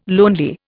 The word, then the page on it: lonely: Indian